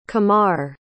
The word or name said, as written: qamar